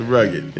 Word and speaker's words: Rugged: It's The Rugged, yeah. – Now, that's spelled R-U-G-G-E-D.